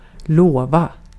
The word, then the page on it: promise: [ˈpɹɑm.əs]; /ˈpɹɒ.mɪs/; /ˈpɹɑ.mɪs/